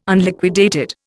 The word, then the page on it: Unliquidated: Indian